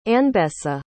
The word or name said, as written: Anbessa